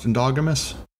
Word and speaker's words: endogamous: I'm just going to confirm, it is pronounced endogamous? Seems stupid. I looked it up. It says endogamous.